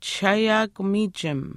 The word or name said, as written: chishaayaakumiichim